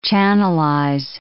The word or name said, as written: channelize